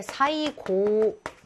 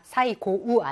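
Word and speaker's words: 사이고 (最後): 예, 또 그다지 많이 안 나오는데 사이고 짧습니다. 사이고우 아니에요.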